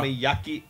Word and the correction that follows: Okonomiyaki: I forget how to pronounce it. I know it. I've seen it in a lot of anime. What's it called? Okonomiyaki. That's what it is.